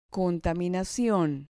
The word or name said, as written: contaminacion